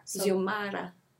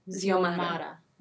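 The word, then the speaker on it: Xiomara: How do you say her name? Samara? – Ziomara. – Ziomara.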